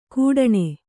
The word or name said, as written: ಕೂಡಣೆ